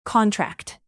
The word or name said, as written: contract